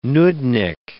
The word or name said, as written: nudnick